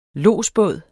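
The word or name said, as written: Lodsbåd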